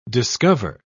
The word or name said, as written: discover